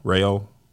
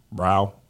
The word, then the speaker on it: Rao: Yeah, that's the brand. R-A-O, Ryo, Ryo.